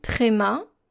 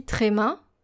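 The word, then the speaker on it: ambiguïté: And this word is spelled A-M-B, I, G, U, I, tréma.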